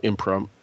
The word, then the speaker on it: imprimatur: But I just thought it was wonderful that she volunteered to give that award, that she really wanted to give her imprimatur. Some people pronounce that word differently, so I apologize for those of you who do. But in Catholic schools, that's the way we're taught to say it.